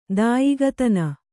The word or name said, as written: ದಾಯಿಗತನ